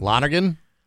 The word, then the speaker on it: Lonergan: It's Lonegran, I think, isn't it? – Lonergan? – Oh, maybe that's what it is.